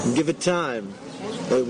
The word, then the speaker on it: Givatayim: Givatayim, which is right outside of Tel Aviv.